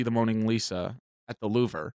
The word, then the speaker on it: Louvre: I very recently went to the Louvre. – Right. – Which is how it's properly pronounced.